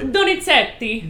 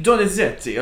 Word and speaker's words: Donizetti: opera by we were talking about the composer earlier, and you pronounce it so beautifully. – Donizetti. – Donizetti.